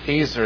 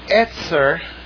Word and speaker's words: Ezer: And Ezer, as we say it in English, or mispronounce it in English from the Hebrew, is a word that simply means to help. Etzer, more, almost 100%, with one exception, almost 100%, Etzer is a description of God.